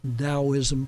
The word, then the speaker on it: Taoism: Taoism, pronounced with a D but spelled with a T,